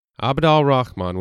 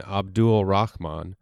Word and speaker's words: Abd al Rahman: Abd al Rahman was his full given name, essentially his first name. Sometimes pronounced Abd al Rahman or even Abdul Rahman, Abd al Rahman is translated from the Arabic as the servant of the most gracious.